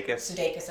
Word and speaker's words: Sudeikis: Yeah, and one of the things I do like about it, too, is aside from Jason Sudeikis, although I probably mispronounced it. – Sudeikis, I always mispronounce that, thank you.